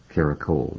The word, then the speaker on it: Caracole: Tell me what you were trying to say in Caracole. I'm pronouncing it correctly. – You are, absolutely.